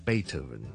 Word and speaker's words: Beethoven: OK，跟住我哋到莫扎特，我哋通常咧英文咧发音系 Mozart。贝多芬咧系 Beethoven。